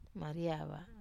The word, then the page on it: Mariava: Wamesa